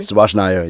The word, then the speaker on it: Subhas Nair: Am I pronouncing that correctly? – Subhas Nair, yeah, you got it.